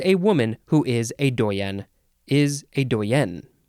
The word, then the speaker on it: doyen: Doyen. You emphasize the second syllable here. Doyen or dwayen.